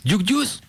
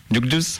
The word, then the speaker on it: Duke Deuce: Duke Deuce. – Duke Deuce, ok.